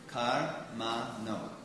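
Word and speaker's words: karmaṇo: For instance, in text 17, you see the first word is three different syllables. Kar-ma-no. So it's kar-ma-no.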